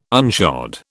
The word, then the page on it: unshod: British English